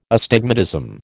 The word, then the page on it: astigmatism: American English